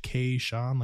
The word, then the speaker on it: Kaishon: I'm Kaishon. Like I said before, that's pronounced Kaishon, like the letter K in the name Sean.